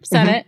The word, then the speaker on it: Sennett: Sennett.